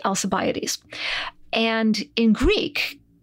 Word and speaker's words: Alcibiades: Alcibiades is totally a legit way to pronounce his name when you're talking about him in English.